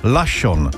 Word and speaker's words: Larsson: Písmena R a S v tomto případě splývají dohromady, přičemž ve výslovnosti R být úplně nemá, takže tam se R nevyslovuje, takže Larsson by měl znít jako Lašon.